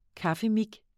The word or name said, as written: kaffemik